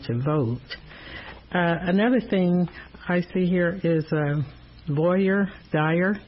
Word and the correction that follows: voir dire: Did I pronounce that correctly? – I think it's Voir dire. – Voir dire.